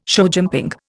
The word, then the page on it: showjumping: Indian